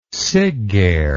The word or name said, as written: siggeir